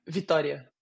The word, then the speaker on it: Vitória: And how do you pronounce the city again? – My Victoria? – city? Vitória. Yeah. – Victoria. So it's almost it's, so you say it real quick.